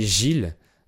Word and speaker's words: Gilles: Notre hôte s'appelait Gilles. Alors, c'est un prénom masculin qui s'écrit G-I-L-L-E-S. Mais en fait, la deuxième moitié du mot se prononce pas. Si on l'écrivait G-I-L, ça se prononcerait pareil.